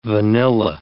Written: vanilla

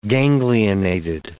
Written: ganglionated

